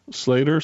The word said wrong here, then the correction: Sleater: Sleater. – S-L-E-A-T-O-R. Sleater. – Sleater. Okay.